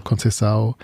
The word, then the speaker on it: Conceição: Conceição.